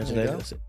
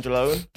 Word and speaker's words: Angeloni: Michael Accadere, Vittorio Angeloni, is it? – Yeah, Angeloni. – It. I always feel like I say it wrong. – Yeah, Angeloni. – I always feel like I'm saying Angelone.